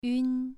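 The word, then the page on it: yun: tone 2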